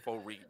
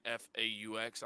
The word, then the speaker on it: faux: And I think it's called faux racism. I think it's spelled like FAUX.